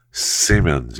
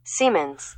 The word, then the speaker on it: Siemens: Siemens. – That's what I thought. That's what I said. – Siemens. – Thank you. You don't have to repeat it to me. I got it the first time. Siemens.